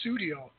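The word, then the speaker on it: Sussudio: And I botched the name. I botched Susodio. That's how you say it.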